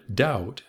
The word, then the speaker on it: doubt: Without question, the word doubt has a silent B.